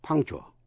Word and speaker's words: punctual: Âm c trong chữ punctual cũng phát âm như k. Thân lưỡi chỉ để vào vị trí sau hàm x, punk, chỗ cuối mềm, nhưng không phát âm k thành tiếng. Rồi tiếp ngay sang chùa. Quý vị nghe lại. Punk, chùa, punctual. Punctual, P-U-N-C-T-U-A-L, punctual, là đúng giờ.